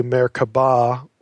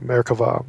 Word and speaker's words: Merkava: And Merkava can either be spelled with a B, Merkaba, or Merkava with a V. It's pronounced V if you're saying it in Hebrew, Merkava, mysticism.